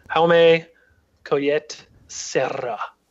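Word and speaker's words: Jaume Collet-Serra: So what I know is the director was born in Spain, so I'm pronouncing his name as I know how to speak Spanish, Jaume Collet Serra.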